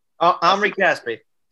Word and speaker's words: Omri Caspi: Omri Caspi.